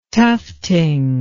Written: tufting

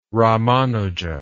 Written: ramanuja

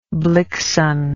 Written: blixen

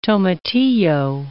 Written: tomatillo

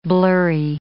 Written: blurry

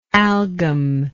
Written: algum

